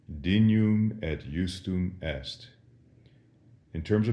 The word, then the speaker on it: dignum: Dignum would be classical Latin, but in ecclesiastical Latin, the GN is massaged a little bit, and we say it in the way that we would say in English, canyon, dignum.